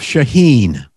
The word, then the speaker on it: Sheheen: gotta learn how to pronounce his name properly. You said it wrong too. It's Ralph Sheheen.